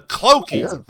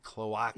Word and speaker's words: cloaca: The cloaky. The cloacy. Cloaca.